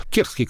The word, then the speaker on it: терские: Терские, кстати, кажется, правильно, э, правильно ставить ударение, хотя я слышал оба варианта. Но сами они говорят терские.